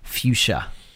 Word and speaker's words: fuchsia: Yeah, it's almost like it's like a hybrid between violet and fuchsia. Is that how you pronounce that color? – Yes, but yeah, these are more fuchsia.